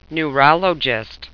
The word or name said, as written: neurologist